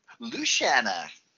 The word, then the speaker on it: Lucía: And I'm Shan- Luciana.